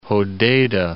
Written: Hodeida

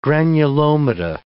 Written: granulomata

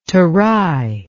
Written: Tarai

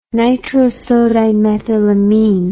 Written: nitrosodimethylamine

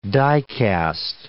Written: dicast

